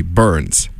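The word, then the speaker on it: Børns: We got Matt and Kim, Shaggy, and Burns, except I pronounce his name Burns because, fun fact, the O in his name, it's got like a little cross, like, it's got like a little slash across it, and that is, in fact, it's a Norwegian letter, and I know a bit of Norwegian, so I know that that's not pronounced with like O, that's pronounced E, so it's actually Burns.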